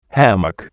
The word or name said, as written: hammock